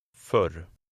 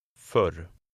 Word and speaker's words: för: Before two consonants, the dotted O makes a shorter sound, something like the E in her. Listen and repeat number 9. – För. För.